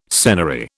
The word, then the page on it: senary: British English